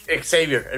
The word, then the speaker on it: Xavier: Y entonces el tema es que Gabriel, ni el mismísimo profesor Javier X pudo haber enfrentado se pronuncia Javier o Xavier. – Xavier, el profesor Xavier.